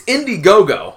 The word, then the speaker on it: Indiegogo: It's Indiegogo. I don't know why I want to stick a letter A in there, another syllable, but I do.